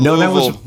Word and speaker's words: Louisville: The thing about Louisville, my wife taught me, is you say it like you've had several bourbons.